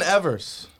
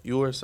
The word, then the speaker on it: Ewers: Quinn Ewers. Am I saying it right? Yours? I think it's yours. Quinn Ewers.